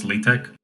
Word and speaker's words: LaTeX: It's written LaTeX, but I think it's pronounced LaTeX.